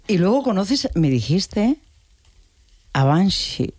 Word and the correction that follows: Banksy: en España la gente lo dice de otra manera, ¿no? Pero bueno, como Banshee. – Esas palabras... Es Banksy. – Banksy.